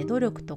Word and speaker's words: 努力: 努力と書いて癖と読む